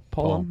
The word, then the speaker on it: Pullman: Pullman.